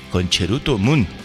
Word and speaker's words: Concerto Moon: Eh, bueno, los, los japoneses lo pronuncian como Concheruto Moon.